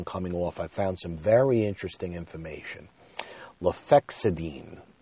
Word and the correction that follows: lofexidine: uh, lofexidine, lofexidine or lofexidine. I'll spell it for you. L O F E X I D I N E.